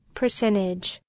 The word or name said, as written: percentage